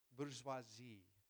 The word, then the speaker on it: bourgeoisie: Bourgeoisie, that's it.